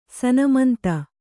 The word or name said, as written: ಸನ್ಮಂತ